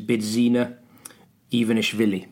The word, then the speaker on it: Bidzina Ivanishvili: His name is Bidzina Ivanishvili. – Very nice. – Yeah, that's correctly pronounced. – Good. – Maybe not. – Well, I can't. Yeah, I can't question your Georgian pronunciation.